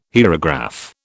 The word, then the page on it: hierograph: British English